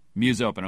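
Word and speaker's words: Musopen: Did I get that right? Muse Open, or how do I pronounce it? – You know, domains are hard to come by, so anything works. Muse Open is what I go by.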